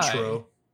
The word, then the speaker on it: Cointreau: Cointreau.